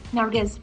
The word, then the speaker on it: Nargis: Do I say Nargis or Nike? Like, the S is the S pronounced? – Nargis. – Nargis. Okay, perfect.